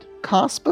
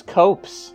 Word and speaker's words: copse: It's not copse. – Is it cusp? How do you pronounce it? – Cops. – Cops. Okay, I'll say it again. – C-O-P-S-E. – I don't know why you said corpse. – It's real close to corpse.